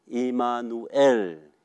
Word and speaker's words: 以马内利: 我与你们同在，我们应该大家都知道以马内利吧？以马内利希伯来的正确的发音是以马努埃尔，以马努埃尔。